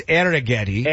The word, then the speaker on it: Arrighetti: Well, you could say his name. It's Arrighetti. – Arrighetti.